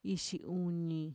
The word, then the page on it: ixiuni: Mixtec